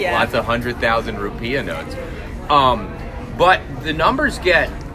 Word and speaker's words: rupiah: The currency is called the rupiah. – Yeah, the currency is the rupiah. Rupiah. I don't know why I decided to mispronounce it.